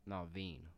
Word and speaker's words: Naveen: Prince Navid from... – Naveen. – How do you spell it? – N-A-V-E-E-N.